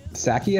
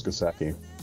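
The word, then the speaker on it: Psaki: Like, and it's got a silent P in the front of it. Saki, I think, is what it is. Let me look it up real quick. Yeah. – Saki.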